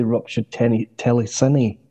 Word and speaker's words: Ruptured Telescene: It's actually Ruptured Telescene. I should have put the accent on the E, I guess.